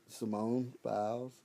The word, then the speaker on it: Simone Biles: Simone Biles, is that how you say her name? – Yeah.